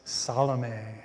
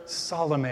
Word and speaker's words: Salome: The girl's name, according to the Roman historian Josephus, was Salome, or Salome, I guess, depending on whether or not you've watched the 1953 movie starring Rita Hayworth, and I do not recommend it. Anyway, that's how they pronounced her name, but I prefer Salome. It's just more poetical and sounds a little bit less like salami.